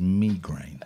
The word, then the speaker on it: migraine: Turns out the correct pronunciation of that is migraine. Not on my watch, it's not. It is because uh, it's from the Greek.